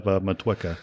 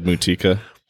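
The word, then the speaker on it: Motueka: And this one happens to have Matueka in it. So a little New Zealand fun there. – That's how you say it. – Yeah. I've always said Mutika, but Matueka, sure, that works. Yeah.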